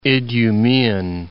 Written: Idumean

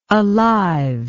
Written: aliveness

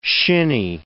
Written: shinny

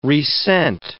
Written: resent

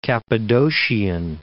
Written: Cappadocian

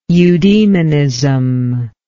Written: eudemonism

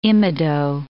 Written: imido

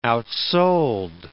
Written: outsold

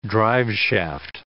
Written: driveshaft

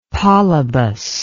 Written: Polybus

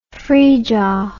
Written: frija

